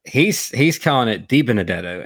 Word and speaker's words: DiBenedetto: Now, DiBenedetto.